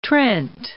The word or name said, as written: Trent